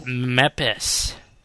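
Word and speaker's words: MEPIS: Meepus. – No, that's Brad's favorite Linux distribution, isn't it? – Meepus. – It's pronounced Meppis.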